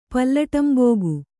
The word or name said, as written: ಪಲ್ಲಟಂಬೋಗು